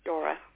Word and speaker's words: Dora: It must be spelled like B-A-L-L-E-S. T-E-R-O-S? – Correct. – And your first name? – Dora. D as in David. – Dora. – Yes. – It's D-O-R-A, right? – Correct.